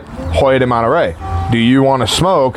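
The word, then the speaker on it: Hoyo de Monterrey: Hoyer de Monterey, yeah.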